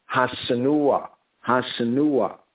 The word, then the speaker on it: Hassenuah: Here is spelled as H-A-S-S-E-N-U-A-H. So we have Senua and the definite article Ha. Ha, Senua, right? Hasanua, Hasanua. Now it's spelled in the A-V as H-A-S, one S-E-N-U-A-H in 1 Chronicles 9 and 7.